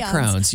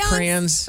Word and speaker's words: crayons: Well, I mean, you didn't say crayons. You said crayons.